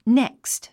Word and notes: next: nékst; ネェクスト

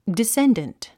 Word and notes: descendant: diséndənt; ディッセンデント

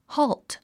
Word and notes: halt: hɔ́ːlt; ホールト

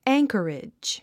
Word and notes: anchorage: ǽŋkəridʒ; アンカレジ

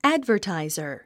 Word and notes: advertiser: ǽdvə r tàizə r; アドバァタイザァ

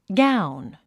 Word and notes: gown: ɡáun; ガウン

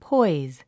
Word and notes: poise: pɔ́iz; ポォイズ

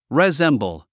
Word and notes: resemble: rizémbl; リゼンボゥ

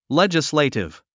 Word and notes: legislative: lédʒislèitiv; レジスレイティブ

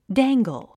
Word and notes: dangle: dǽŋgəl; ダンガル

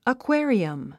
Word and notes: aquarium: əkwé ə riəm; アクエリアム

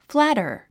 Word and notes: flatter: flǽtər; フラァタァ

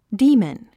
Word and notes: demon: díːmən; ディーモン

